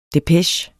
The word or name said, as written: depeche